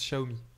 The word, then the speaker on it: Xiaomi: Euh, on prononce Xiaomi.